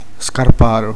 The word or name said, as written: calzolaio